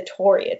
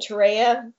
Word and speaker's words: Torreya: And so one thing is, I grew up saying Torreya because that's what I learned, but apparently the correct pronunciation is Torreya because the tree is named after John Torrey.